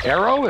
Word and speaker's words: Arroe: Arrow, am I saying that right? – You're absolutely saying it right, sir.